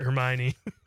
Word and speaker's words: Hermione: Paula Krieger, Conrad's mom, says Emma Watson as Hermione. Am I saying that right? I didn't see that. Hermione? What is that word? – Hermione.